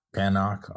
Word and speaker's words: Panoch: Panoch.